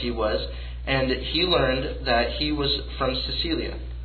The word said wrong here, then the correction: Cilicia: Did I pronounce that right? Cilicia, excuse me.